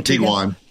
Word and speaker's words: Tiguan: Duck, we have a mutual friend, Lee Denneke, who owns a Tiguan. Is it? Well, I believe it's how it's pronounced. The Volkswagen Tiguan.